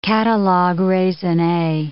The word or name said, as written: catalogues raisonnes